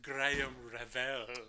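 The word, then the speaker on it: Graham Revell: It's actually pronounced Graham Revel.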